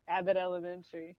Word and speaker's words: Abbott Elementary: Is it Abbott? – Yeah, Abbott Elementary. – Abbott Elementary.